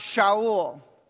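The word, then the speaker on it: Shaul (שאול): Saul's name in Hebrew is pronounced Shaul. Shaul comes from the Hebrew word sha'al, which means to ask.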